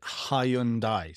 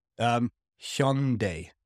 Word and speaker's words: Hyundai: British people say it Hyundai, three syllables, also wrong. – Oh. – And I lived in Korea, so the Korean pronunciation is different to both. – Okay. – So they're both wrong. – How do Koreans pronounce it again? – Hyundai, like H-Y-O-N or H-Y-E-O-N. – Okay. Hyun.